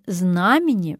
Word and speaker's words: знамение: Даем правильный ответ. Знамение. Ударение зафиксировано нормой и восходит к словам «знак», «знамя». Так ведь легче запомнить.